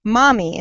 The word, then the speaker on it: Mahmee: And today my guest is Melissa Hanna, the founder and CEO of Mahmee. And did I say that correctly? – Yes.